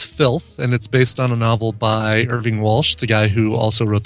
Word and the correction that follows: Irvine Welsh: the book that became I'm sorry, Irvine Welsh, not Irving Walsh, Irvine Welsh. Seems appropriate for this segment to slightly mispronounce a name.